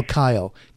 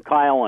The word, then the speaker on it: Keil: Oh, Kyle. K-E-I-L and Dalidge. They were two Jewish Messianic believers. – Oh, I pronounce him Kyle and Dilts.